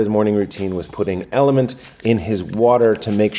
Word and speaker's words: LMNT: So he kept saying, I put Element in my water, but I couldn't find it when I searched it. It's because it's LMNT. It's spelled L, the letter LMNT.